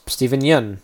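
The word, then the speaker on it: Steven Yeun: Stephen Yeun, that's how you pronounce it.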